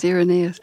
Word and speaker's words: Irenaeus: Now, you credit Irenaeus, am I saying his name right? – Yes, Irenaeus.